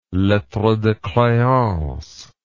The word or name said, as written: lettre de creance